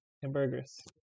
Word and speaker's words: ambergris: I always thought it was ambergris, but it looks like here you can also pronounce it ambergris.